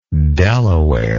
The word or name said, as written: De La Warr